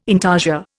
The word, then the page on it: intarsia: Indian